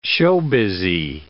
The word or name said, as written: showbizzy